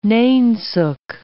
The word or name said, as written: nainsook